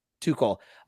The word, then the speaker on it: Tuchel: I think even Antonio Conte, there was a moment when Chelsea scored their second goal that is it Thomas Tuchel? I don't know how to say his name. I don't really follow the team that much. – Tuchel.